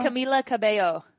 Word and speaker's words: Camila Cabello: Did I say it right? – Camila Cabello. – Camila Cabello, her new album, right.